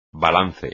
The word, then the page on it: balance: espagnol